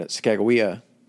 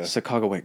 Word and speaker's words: Sacagawea: And Sacagawea, did I pronounce that right? – Sacagawea. – God damn it.